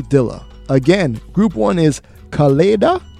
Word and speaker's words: Kaleida: K-A-L-E-I-D-A.